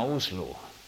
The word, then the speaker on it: Oslo: Ma arvan, et see norra keeles on Monsen, vist loetakse Monsen, sest nemad hääldavad ka Oslo linna Uslu.